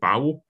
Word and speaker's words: Bao: She got her directing sort of debut doing the Pixar short, I think it's pronounced Bao, B-A-O, the one with the little dumplings in it, sort of group memories of a baby.